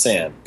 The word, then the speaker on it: San: Programmer Jez San, I hope I'm getting that correct. I've read it in like Edge magazine a couple times. – I think it's pronounced San, but yeah, Jez San.